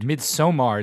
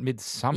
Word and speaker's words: Midsommar: Midsommar. It's not midsummer.